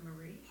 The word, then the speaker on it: Maree: And M-A-R-E-E, yes. Always. – Marie? You pronounce that Marie? – Uh-huh.